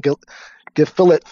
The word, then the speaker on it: gefilte: I'm filing a lawsuit against McDonald's for not selling gefilte fish.